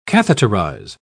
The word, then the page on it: catheterise: British English